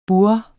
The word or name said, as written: burre